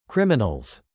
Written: criminals

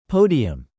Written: podium

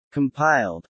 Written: compiled

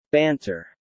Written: Banter

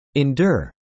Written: endure

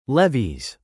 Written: levies

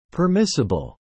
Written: permissible

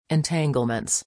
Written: entanglements

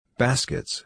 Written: baskets

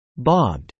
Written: bogged

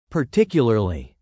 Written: particularly

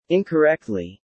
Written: incorrectly

